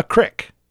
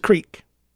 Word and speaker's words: creek: It's not a crick. And if you say crick, you're saying it wrong. It's a creek. It's spelled creek. Crick is C-R-I-C-K. Creek is C-R-E-E-K.